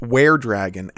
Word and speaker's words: were-dragon: It's pronounced were-dragon, as in werewolf, not we were."